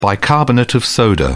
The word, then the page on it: bicarbonate of soda: British English